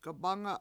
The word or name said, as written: gabAnga7